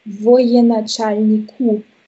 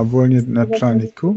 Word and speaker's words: военачальнику: Aber das spricht man Военачальнику aus. – Ah, Военачальнику.